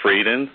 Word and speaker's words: Frieden: Here to discuss the Vital Sign report is CDC Director Thomas R. Frieden, that's spelled F-R-I-E-D-E-N.